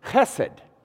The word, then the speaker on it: chesed: The word that's translated as steadfast love is, and I'll pronounce it in Hebrew here, it's pronounced chesed. Chesed.